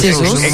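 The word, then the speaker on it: Jesus: Pero es Jesús, muy portugués.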